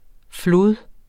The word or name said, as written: flod